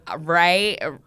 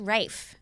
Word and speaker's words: Ralph: No, it's pronounced Rafe. – Rafe. Oh. – Yeah. Or Ray Fiennes. It's either pronounced Rafe or Ray. – I thought it was Ralph Fiennes. – It's spelt that way. But it's pronounced either Ray or Rafe.